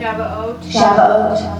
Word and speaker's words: Shavuot: Shavuot. – Is that when Pentecost and, okay. – Yeah. – Is that, they basically are, both of them are marriages. But it said Passover to Shavuot means coming out of slavery, but Yom Kippur to Sukkot means the marriage.